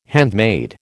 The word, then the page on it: handmaid: American English